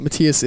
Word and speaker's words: Matthias: It's pronounced both ways, but Matthias is a proper way.